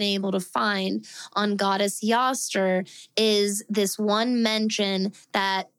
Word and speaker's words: Eostre: There is literally one mention of the goddess name Yoster, which is spelled E-O-S-T-R-E.